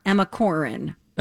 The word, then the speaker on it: Corrin: We are, from a newcomer, Emma Corrin. – Emma Corrin. – And it's spelled like Corrine or Corinne.